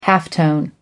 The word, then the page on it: halftone: American English